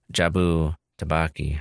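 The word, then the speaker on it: Jobu Tupaki: So Joy, aka Jabu Tabaki, I said it right this time, is the main antagonist.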